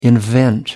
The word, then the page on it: invent: American English